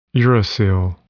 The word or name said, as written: uracil